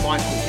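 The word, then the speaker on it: Micheal: Also, connect with me on Facebook at I Am Michael Hilton.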